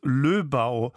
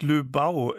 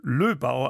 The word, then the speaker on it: Löbau: Löbau und nicht Löbau, wie ich sagte. Hörerin Greta Bagno, vielleicht mache ich jetzt schon wieder was falsch, Greta Bagno hat mich darauf hingewiesen, dass Löbau, also auf der ersten Silbe, betont wird.